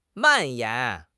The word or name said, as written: 蔓延